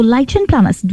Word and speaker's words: lichen planus: Okay, so lichen planus.